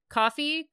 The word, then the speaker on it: Ko-fi: Coffee, Ko-fi, however you say it, again at Epsilina. – It's just coffee. – It's coffee, right? – You know, you're buying a cup of coffee.